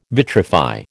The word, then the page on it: vitrify: American English